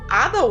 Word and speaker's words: Ada: E aquela Eida, gente, a dublagem cagou. Ada Wong, please. Please.